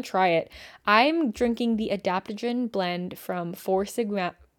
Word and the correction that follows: Four Sigmatic: Fuck. I didn't pronounce that correctly. I'm drinking the adaptogen blend from Four Sigmatic.